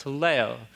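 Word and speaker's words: teleo (τελέω): The word is pronounced teleo. Teleo. Why don't you say it with me? Teleo.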